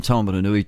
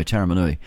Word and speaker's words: Taumarunui: Taumarunui to Kapiti. Oh, gosh, did I pronounce that wrongly? I did. Sorry, Taumarunui to Kapiti.